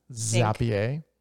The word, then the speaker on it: Zapier: Because I think what we want to start using Zapier. Is that how you pronounce it? – Yes. – Yeah. Zapier.